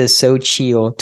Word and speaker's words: Xochitl: But that is the Xochitl tortilla chips.